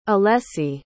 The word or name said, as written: alesi